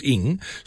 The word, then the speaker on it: Ng: Campbell says he had a teacher at school called Miss NG, which is Ing, they say pronounced Ing, so she pronounced it Missing.